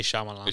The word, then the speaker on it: Shyamalan: How do you say his name? – Shyamalan. – The first name? – I say Shyamalan.